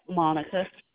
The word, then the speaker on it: Monnica: Monica. – Spell it for me, please. – M-O-N-N-I-C-A.